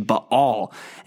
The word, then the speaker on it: Baal: I guess we've seen him earlier, but he'll be a main feature in these coming chapters, the false god Baal, if you want to go with a more Hebrew pronunciation, Baal.